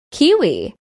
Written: kiwi

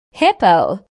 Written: hippo

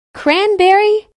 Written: cranberry

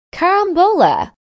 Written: carambola

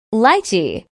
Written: lychee

